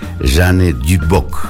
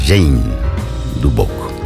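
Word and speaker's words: Jane Duboc: 12:35, escuchemos a continuación a una cantante maravillosa de Brasil, tiene una voz muy educada, se llama, bueno, ya se pronuncia así, Jeanne Dubock, en inglés sería Jane Dubock.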